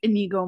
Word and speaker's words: Inigo: I call him like Inigo Montoya.